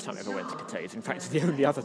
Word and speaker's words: Cathays: which is at Cattays Park, but it's spelled C-A-T-H-A-Y-S. As in cafes, and nobody would understand us.